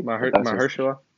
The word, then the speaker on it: Mahershala: I think it's Mahershala Ali. – Mahershala.